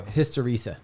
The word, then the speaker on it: hysteresis: Hysteresis.